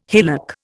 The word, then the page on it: hillock: Indian